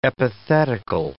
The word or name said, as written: epithetical